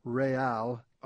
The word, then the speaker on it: Real: by calling it Real, R-E-capital-A-L, so that everybody out there pronounces it correctly.